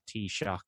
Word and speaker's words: Taoiseach: I would say, I think of like a cup of tea and then shock. Like, oh, I just got shocked by that Taoiseach.